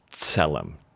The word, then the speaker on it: tselem: The Hebrew word for image is tselem. We would spell it T-S-E-L-E-M, tselem.